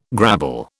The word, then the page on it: grabble: British English